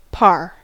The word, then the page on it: par: American English